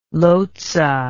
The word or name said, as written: lotze